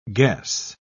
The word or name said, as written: guess